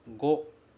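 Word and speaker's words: go (五): E ele se pronuncia go.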